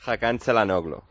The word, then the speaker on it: Hakan Çalhanoğlu: Hakan Çalhanoğlu.